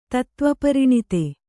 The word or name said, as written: ತತ್ತ್ವಪರಿಣಿತೆ